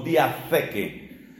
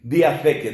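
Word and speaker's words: diatheke (διαθήκη): Lo primero que hay que saber es que estas palabras testamento en español son la traducción de una palabra griega que se escribe diateke con la palabra K, con la letra K, diateke. Además, esa T va con una H, se pronuncia algo así como diatheke, diatheke, diateke con TH y con K.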